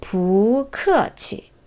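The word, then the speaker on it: 부크치 (不客气): 부크치 부가 두 가지 성조라고 했었는데 왜두 가지냐면요. 음, 지금처럼 뿌 곧바로 나오는 그다음에 글자의 발음이 뿌와 똑같은 사성이면 사성을 두 번 연거푸하지 않고 그 앞에 부를 이성으로 발음해줘요. 조금 복잡한 것 같아도 몇 번 해보시면 이해되실 거예요. 그러니까 뿌, 크, 치는 세상에 없습니다. 부크어치 크가 사성이니까 부크어치로 해줘요. 부크치 조금 너무 가볍지 않나요?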